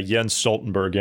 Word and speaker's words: Jens Stoltenberg: Oh, yeah, Jens Stoltenberg, yeah.